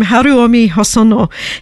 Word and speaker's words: Haruomi Hosono: So I know how to pronounce this guy's name, Haruomi Hosono.